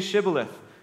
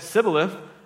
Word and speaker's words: Shibboleth: If he answered no, they told him, please say Shibboleth. If he said Sibboleth, because he could not pronounce it correctly, they seized him and executed him at the fords of the Jordan.